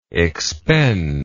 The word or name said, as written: expender